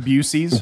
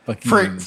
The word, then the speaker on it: Buc-ee's: I do love the people that intentionally mispronounce it. Bucy's. – Bucy's. – That's my favorite. – I just think it's funny that it's Buck, B-U-C. – Oh, yeah. – Hyphen E-E-S. – Yeah. – Buc-ee's. For years driving into Texas,